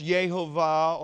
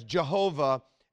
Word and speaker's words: Jehovah: Now, it was likely uh, that Jehovah at that time was pronounced Yehovah or Yehovah, uh, but it's been pronounced in English as Jehovah ever since of William Tyndale.